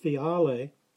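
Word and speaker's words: phiale (φιάλη): Both words come from the Greek phiale, pronounced phiale, which describes a shallow,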